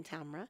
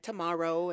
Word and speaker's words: Tamra: Actually, my grandmother named me Tamra. Rhymes with camera. Okay. So all around the world, Muslims are calling me things like tomorrow and Tamara and things like this. But I'm working on helping them pronounce my name correctly. It's Tamra. Yeah.